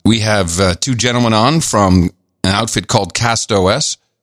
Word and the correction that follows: Castos: That's how I pronounce it. I may be wrong. – Castos. – Is it Castos? I like CastOS, but okay, Castos.